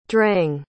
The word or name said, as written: dreng